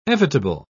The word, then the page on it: evitable: British English